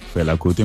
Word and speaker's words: Fela Kuti: Fela Kuti. – Fela Kuti, muy bien.